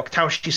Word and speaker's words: Wachowski: In the Waktoshi, in the Waktoshi's, I can't pronounce the name. – Which Um, Carol, Annabelle, please, Carol. Um, that those directors, um, the uh, Wachowski sisters, um, The Matrix.